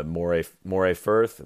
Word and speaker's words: Moray Firth: the Moray Firth. Am I saying it right? – No, you're doing well.